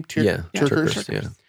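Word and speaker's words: TerKeurst: Both you and Lisa Turkhurst, is that how you say her name? – Turkhurst. Yeah.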